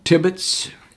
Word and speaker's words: Tibbitts: My name is Ted Tibbetts, T-I-B-B-I-T-T-S.